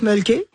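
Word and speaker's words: Mölkky: On prononce mulky. – Ouais. – Et c'est M-O-L-K-K-Y.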